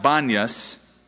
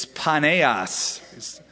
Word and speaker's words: Paneas: And it's actually a mispronunciation. It's not Banyas, it's Paneas. It was a shrine to the Greek god Pan or Paneas.